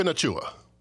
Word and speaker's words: Nacua: Puka Nacua. – Nacua. How is N-A-C-U-A Nacua?